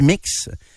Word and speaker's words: Mx: Je, je, je, ne connaissais pas l'expression mix, qui se prononce mix, mais qui s'écrit MX.